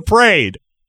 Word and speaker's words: parade: And good for him for using the Philadelphia pronunciation. We went to the parade. – The parade.